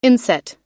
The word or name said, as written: inset